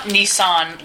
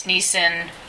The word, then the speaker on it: Nissin: Nissan is, and it's not Nissan like the car company, it's Nissan. – N-I-S-S-I-N. – Yeah, because mm-hmm. I's in Japanese are always pronounced E.